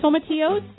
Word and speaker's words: Tomatillos: And tomatillas? Am I saying it right? – Tomatillos.